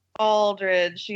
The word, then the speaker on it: Aldridge: and then some guy named Lamarius Albright. – Poor LaMarcus. You still haven't forgiven him, huh? – Aldridge. Yeah, I mispronounced his name.